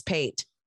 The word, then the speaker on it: Pate: The last name was Pate, like P-A-T-E, so... One word, one syllable, very, very, very country.